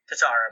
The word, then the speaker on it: Tatar: Well, if you look on Daily Faceoff, Tatar, is how you say it? – Tatar, I believe, yes.